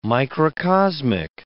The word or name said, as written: microcosmic